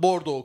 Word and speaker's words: Bordeaux: Çünkü telaffuzda o harflerin hiçbirini okumuyoruz. Bordeaux yazıyor adam, Bordeaux okuyor.